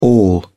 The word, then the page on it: all: British English